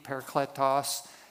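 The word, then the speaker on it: parakletos (παράκλητος): it's a Greek word that, that is pronounced paraclete, parakletos, and it, it simply means one who comes alongside of.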